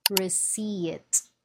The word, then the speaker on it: receipt: Receipt. Receipt. The P is silent.